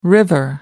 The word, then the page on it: river: American English